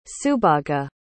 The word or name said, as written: subhaga